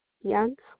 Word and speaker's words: Young: Audrey, A-U-D-R-E-Y. – Okay, and your last name? – Young. – Can you spell it for me? – Y-O-U-N-G.